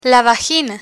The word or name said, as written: vagina